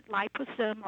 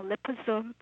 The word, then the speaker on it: liposome: And for people who are into makeup, if you go to the stores or you read magazines, you have seen that word, liposome or liposome, as some people pronounce it.